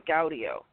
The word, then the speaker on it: Gaudio: As my partner Jack has pointed out on many occasions, my maiden name and part of my current last name starts with Gaudio. I tell people to spell that as G-Audio. I am an audio person.